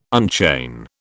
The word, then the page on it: unchain: British English